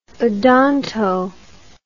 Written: odonto

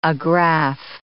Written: agraffe

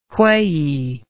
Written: wheyey

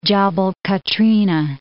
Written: Jabal Katrinah

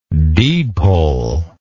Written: deedpoll

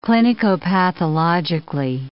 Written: clinicopathologically

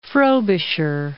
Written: Frobisher

